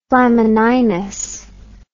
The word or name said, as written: flamininus